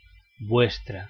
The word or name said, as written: vuestra